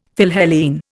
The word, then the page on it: Philhellene: Indian